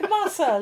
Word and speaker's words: Massa: M-A-S-S-A.